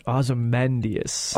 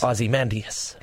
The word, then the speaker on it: Ozymandias: Ozymandias. – Ozymandias.